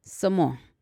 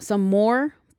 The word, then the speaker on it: Somaah: and the brand of the soda is Samoa. Like some more, but you know, S-O-M-A-A-H. Some more. I think I'm saying it right.